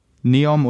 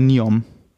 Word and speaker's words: Neom: in Neom oder Niom je nachdem, wie man es aussprechen möchte.